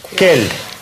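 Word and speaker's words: quel: «Кель». «Кель» – «какая». Пишется по-разному, произносится одинаково.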